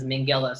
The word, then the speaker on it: Minghella: I mean, I know he's passed away, but his son, Max Minghella, pronounces his last name as Minghella. So... I'm going to call him Minghella.